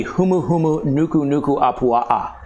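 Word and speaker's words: humuhumu nuku nuku apua'a (humuhumunukunukuāpuaʻa): The triggerfish in Hawaiian would be the humuhumu nuku nuku apua'a. – Wow. – Which means fish with a nose shaped like a pig. The humuhumu nuku nuku apua'a.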